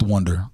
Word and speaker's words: Wunder: BH Ninth Wonder on Instagram, at BH Ninth Wonder. Wonder spelled with a U. WUNDER.